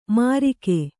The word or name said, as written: ಮಾರಿಕೆ